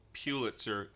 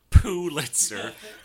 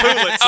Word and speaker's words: Pulitzer: Well, first of all, I think it's pronounced Pulitzer. You said Pulitzer.